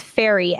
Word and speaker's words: Fieri: Phonetically, they have F E. It's ferry.